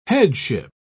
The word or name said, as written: headship